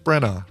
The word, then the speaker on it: Branagh: You put the you don't pronounce the GH. Kenneth Branagh, right? – Branagh.